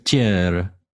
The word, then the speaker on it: Terceira: I definitely said that wrong, but you want to take a shot at it? – Okay. Pronouncing it or the song? – Pronouncing it. – Terceira. – Wow. – Oh, it's Portuguese.